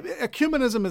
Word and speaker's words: ecumenism: Ecumenism is usually the word.